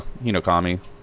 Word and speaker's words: Hinokami: The Hinokami, I think that's how it's pronounced. Chronicles? – Yeah, Hinokami.